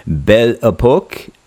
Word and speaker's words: Belle Époque: You have something coming out called the acoustic album and Belle Epoque.